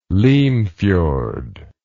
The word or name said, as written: limfjord